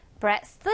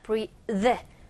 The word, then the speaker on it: breathe: 부분 발음이 breath 아니고 얘 지금 동사잖아. breathe, mother, father 할때그 the 발음으로 해주면 되겠습니다. 읽어보죠.